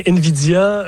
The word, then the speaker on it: Nvidia: Et puis en bourse, la compagnie américaine Nvidia, je ne sais pas si je la prononce comme il faut, continue de fracasser des records. – Oui, c'est bien Nvidia qui connaît des moments fabuleux en bourse, si on peut dire ça comme ça.